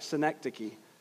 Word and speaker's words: synecdoche: And yes, you heard me right, synecdoche. I will spell that for you. S-Y-N-E-C-D-O-C-H-E. S-Y-N-E-C-D-O-C-H-E, synecdoche.